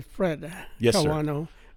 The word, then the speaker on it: Kawano: Well, people call me Fred Kawano, but Fred is just a nickname.